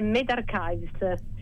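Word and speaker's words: medRxiv: In effetti è Med Archives.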